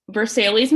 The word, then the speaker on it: Versailles: It is spelled like the palace in France. It is Versailles, Missouri.